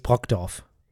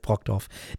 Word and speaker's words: Brokdorf: Ja, der Ort heißt übrigens Brokdorf. – Brokdorf, oh Gott. – Ja, ich weiß, wenn nicht norddeutsche Menschen Orte aus dem Norden aussprechen, das ist ein bisschen schwierig, aber es heißt Brokdorf.